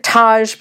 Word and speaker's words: Tej: And this one I liked meeting Roman, and I liked meeting, he pronounces it Taj, but it's spelled Tej, and Tej, I don't really understand why.